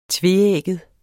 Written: tveægget

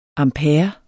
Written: ampere

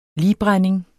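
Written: ligbrænding

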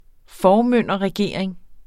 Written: formynderregering

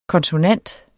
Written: konsonant

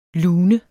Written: lun

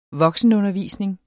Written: voksenundervisning

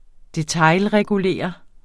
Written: detailregulere

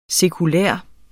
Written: sekulær

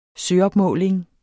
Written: søopmåling

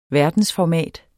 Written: verdensformat

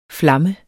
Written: flamme

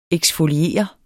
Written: eksfoliere

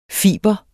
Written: FIBRENES

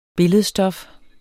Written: billedstof

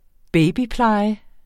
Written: babypleje

